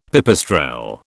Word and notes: pipistrelle: British English

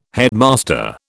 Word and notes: headmaster: British English

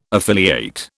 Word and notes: affiliate: British English

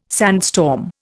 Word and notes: sandstorm: Indian

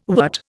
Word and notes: wert: Indian

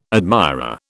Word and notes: admirer: British English

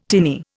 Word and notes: tinny: Indian

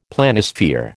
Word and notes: Planisphere: American English

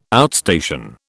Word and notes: outstation: British English

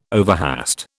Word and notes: overhaste: British English

